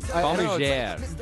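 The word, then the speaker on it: Balmigere: Balmiger. It's French. It's B-A-L-M-I-G-E-R-E. – Balmiger. – Something like that. – I'm going to say it that way. Francois Balmiger.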